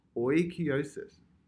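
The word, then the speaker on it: oikeiosis: So this is actually, I guess, somewhat related to another terminology that the Stoics like to use called oikiosis.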